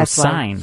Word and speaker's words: Syne: S-Y-N-E. – Oh, Syne.